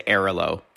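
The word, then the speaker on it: Airalo: That leads us nicely to Airlo.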